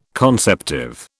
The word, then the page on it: conceptive: British English